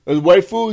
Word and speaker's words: waifu: Waifu, is that how you say it? Oh, it's waifu. Okay, every waifu.